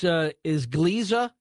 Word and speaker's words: Gliese: Gliese? – Gliese, yeah.